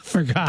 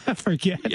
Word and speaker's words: Forget: But he's a neurosurgeon, and his last name is spelled F-O-R-G-E-T. – Forgot. – Dr. Forget. – Forget. – Yeah. Yeah. But he pronounces it Forget.